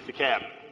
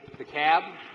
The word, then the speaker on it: DeKalb: Did I pronounce that right, Senator? – DeKalb. – DeKalb? – DeKalb. – DeKalb. – The L silent.